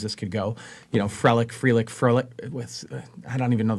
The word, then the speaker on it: Frelick: It's Frelick.